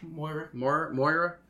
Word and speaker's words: Moira: That's cool to have like a character that can do a little bit of both, because Moira, am I saying that right? – Moira does do damage.